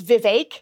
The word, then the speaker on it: Vivek: But I got to tell you, when I went to Iowa – I have been pronouncing his name Vivek for a long time. And it is Vivek.